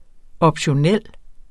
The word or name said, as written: optionel